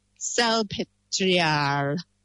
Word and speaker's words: Salpêtrière: but in the L'Hôpital Salpêtrière. How's that? That's very good. Well done. – It's like saltpeter, basically.